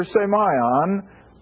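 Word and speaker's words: Semion (σημεῖον): Because this word up here, semion, is always a sign. Now, that's the way I get the pronunciation from a Greek dictionary. Semion. And it should always be translated signs.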